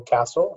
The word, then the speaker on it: Cassell: It's Stephen, S-T-E-P-H-E-N, last name Castle, C-A-S-S-E-L-L. – So you say it like a castle where you'd live, but it is S-S-E-L-L. – That's okay. Correct.